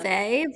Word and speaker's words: Weil: But I think that makes you more cutting, which is why I think you are Simone Weil. – It's not Vey, but that's totally fine. – Yeah. – I'm Simone Vey. But she's French, so you don't need to pronounce it like correctly.